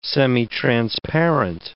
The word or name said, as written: semitransparent